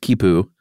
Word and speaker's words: khipu: Is it, I believe it's pronounced Khipu. – Yeah. – K-H-I-P-U. – Or Q-U-I-P-U.